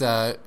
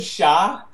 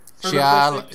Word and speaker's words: Shia: It's Shia LaBeouf. – You just said Sha. – I'm never gonna get it right. – Shia.